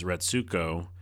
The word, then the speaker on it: Retsuko: Gretsuko? The character's name is Retsuko.